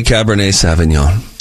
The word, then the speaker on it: Cabernet Sauvignon: It's a Cabernet Sauvignon." And I said, "You know, you can learn a lot about somebody based on how they say Cabernet Sauvignon."